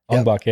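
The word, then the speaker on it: Ong-Bak: And you remember that movie, I'm going to pronounce it wrong, Ong Bak? – Ong Bak, yeah, yeah.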